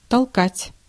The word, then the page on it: толкать: /tɐlˈkatʲ/